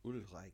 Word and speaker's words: Ulreich: I thought you were going to say, if you're a Bayern Munich fan and you're listening to this, sorry if I pronounce this wrong, Sven Ulreich. – Ulreich.